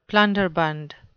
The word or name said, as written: plunderbund